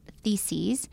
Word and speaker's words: theses: There was a closing quote from this last Sunday, and it was from the first of Martin Luther's 95 theses. Did I say that right? – You did say that right.